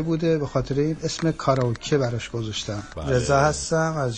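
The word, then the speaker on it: کاراکی (カラオケ): در مورد کاراکی باید بگم که تلفظ شما کاملا درسته. من به عنوان ایرانی که 26 ساله تو ژاپن زندگی میکنم، کاراکی همونی که شما میگین درسته.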